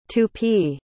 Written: tupi

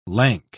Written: lank

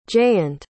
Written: jayant

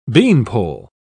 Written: beanpole

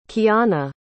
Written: Kiyana

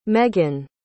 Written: meghann

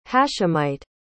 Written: hashemite

